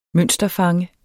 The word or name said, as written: mønsterfange